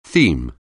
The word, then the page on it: theme: anglais